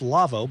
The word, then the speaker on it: Lawo: Our show is brought to you by the folks at Lavo, L-A-W-O, console company, pronounced Lavo, but spelled L-A-W-O.